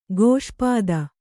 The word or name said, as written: ಕ್ಷಿಪ್ತ